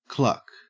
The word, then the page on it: cluck: Australian English